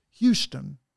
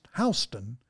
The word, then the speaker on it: Houston: In the same way that if you go to Texas down on the south, you've got the city of Houston. But if you go to Georgia, that same town spelled exactly the same way is pronounced Houston.